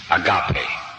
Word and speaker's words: agape: A-G-A-P-E. Agape. Sometimes pronounced agape, but it's agape.